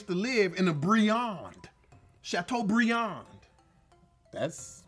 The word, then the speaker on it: Chateaubriand: I want to give it to him really badly, but in the French, you don't pronounce the last letter of the word. Chateaubriand.